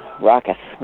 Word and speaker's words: ROCIS: You know, how did that get started, the RAHCUS? And that is, am I pronouncing that right? RAHCUS, R-O-C-I-S. – Yes, RAHCUS. We're not RUSIS, we're RAHCUS. So RAHCUS is an initiative that was it's funded by the Heinz Endowments in Pittsburgh.